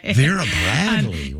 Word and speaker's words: Vera Bradley: I was on standby to see if you knew how to pronounce that. I'm impressed. – Did I do it right? All right. – You did it right. – Vera Bradley.